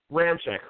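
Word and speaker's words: Ramczyk: So I have them going, and I can never pronounce this guy's name, out of Wisconsin, who is Ramchek. – Ramchek, who has been stated to be the best lineman in the draft.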